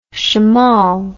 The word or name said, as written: shamal